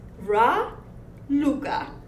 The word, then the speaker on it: Raluca: And I've been called many, many times Racula. And I also have a joke about it. – Okay. – I know I'm coming from Dracula's country. – Uh-huh. – And it's very easy to call me Dracula if you add the D in front of my name. – I never even thought of that. – But my name is Ra. Luca.